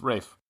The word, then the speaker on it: Ralph: Yeah, Julius Caesar I had as Daniel Day-Lewis, and then Pompey I have as Ralph Fiennes. – Oh, yeah, Rafe. You always say that wrong. It's Rafe.